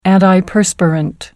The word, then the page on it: antiperspirant: American English